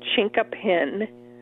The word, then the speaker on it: chinkapin: You could grow, for example, our native chinkapin oak, and it's called chinkapin. If I'm trying to spell it, C-H-I-N-K-A-P-I-N, chinkapin.